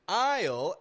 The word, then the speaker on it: aisle: Aisle, A-I-S-L-E, is sounding the exact same as I-S-L-E. Aisle, aisle, same sound, exact same pronunciation. Aisle. This spelling, A-I-S-L-E, does not refer to a landmass.